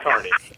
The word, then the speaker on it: Kearney: Now, when you lived in Nebraska, where were you in relation to Kearney, Nebraska? – Kearney? Yeah, come on. We got to pronounce that right. That's Kearney.